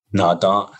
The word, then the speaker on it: nadaa: Nada. So two syllables. Nada. So it'd be N-A-D-A-A. Nada.